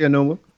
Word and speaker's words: Enunwa: You said it right, Quincy Enunwa.